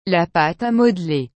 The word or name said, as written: la pate a modeler